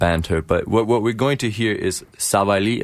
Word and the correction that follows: Sabali: Did I pronounce that correctly? I don't know. Arlington, did I? Yeah, yeah, Sabali.